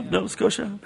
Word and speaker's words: Nova Scotia: I think it's Nova Scotia. Did I say that right? Yeah. Nova Scotia.